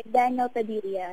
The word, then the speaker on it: Daniel Padilla: Daniel Padilla.